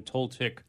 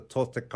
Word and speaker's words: Toltec: I mean, and for those who don't know, what exactly is then Toltec wisdom? And I'm pronouncing it the right way? – Yes, you're correct. Yeah, okay.